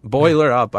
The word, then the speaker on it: Boiler Up: So, hey, Neil Doyle, bowler up. Boiler up. I didn't even say it right. – Nope, you did not.